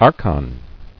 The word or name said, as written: Archonship